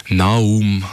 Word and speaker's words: Naoum: also J-U-S-U-F, Naum, also N-A-O-U-M, fast wie der Nao.